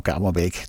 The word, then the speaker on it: Kamerbeek: En Leon Kamerbeek, die deed hier ook mee op de banjo. Zeg ik dat goed? Ja, dat zeg ik goed.